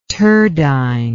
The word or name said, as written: turdine